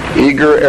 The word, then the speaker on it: Eagar: Eager is spelled E-A-G-A-R. Arizona, 85925. That's the Harvest Trust. P.O. Box 1970. Eager, Arizona, 85925.